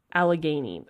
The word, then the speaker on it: Allegheny: Allegheny.